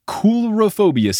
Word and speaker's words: coulrophobia: It is coulrophobia, C-O-U-L-R-O-phobia. So that is a bonus point for Beth.